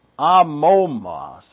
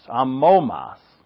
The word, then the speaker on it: amomos: I keep saying anomos. Amomos. Amomos. The first O is a long O, and the second O is a short O.